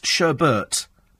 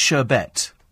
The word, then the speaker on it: Sherbet: Some people think it's Sherbert, but it's Sherbet.